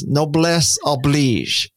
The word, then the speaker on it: noblesse oblige: So number one is noblesse oblige. Is that the right pronunciation? – Yes. Yes, you do, you have an American accent, but it's good. Noblesse oblige. Okay. Yes.